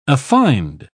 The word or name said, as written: affined